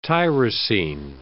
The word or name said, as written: tyrosine